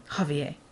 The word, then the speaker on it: Javier: That's how you say it. Javier.